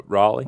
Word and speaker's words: Raleigh: How did you come up with the name uh, Riley? Uh, Raleigh. Raleigh. Sorry. Yeah.